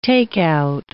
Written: takeout

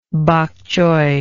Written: bok choy

